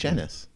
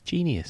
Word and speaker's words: Genus: Is it genus or genius? It's genius edition. – It's genus. – It's genius. – It's spelled G-E-N-U-S.